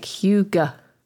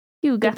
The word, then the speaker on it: hygge: Phonetically, it looks like Hygge. But I'm gonna play this. Will my mic pick this up? – Hygge. – Pick that up? – Hygge.